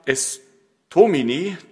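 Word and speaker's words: Estomihi: Heute ist der Sonntag Estomini, der dritte Sonntag vor der Passionszeit. Habe ich das richtig ausgesprochen? Ja.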